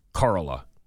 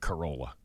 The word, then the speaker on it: Corolla: I think if you're from there, it's Corolla. If you're from outside, it's like a Toyota Corolla.